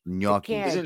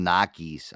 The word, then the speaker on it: gnocchi: Gnocchis. – Cannelloni. – Gnocchi. Cannelloni. – It's gnocchi. The people call them Nakis. Oh, man, that's some Nakis.